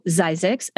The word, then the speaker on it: Zzyzx: So Zizek's, unusual name, it is spelled Z-Z-Y-Z-X,